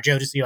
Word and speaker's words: Jodeci: But my actual use screen name is Jodeci Joestar. Jodeci, like the singer.